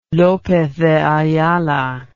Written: lopez de ayala

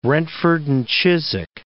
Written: Brentford and Chiswick

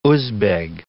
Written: Uzbeg

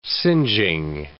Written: singeing

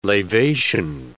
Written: lavation

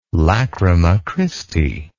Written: Lachryma Christi

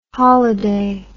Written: holliday

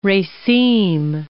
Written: raceme

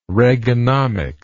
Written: reaganomics

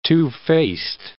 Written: two-faced

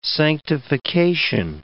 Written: sanctification

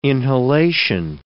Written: inhalation

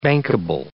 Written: bankable

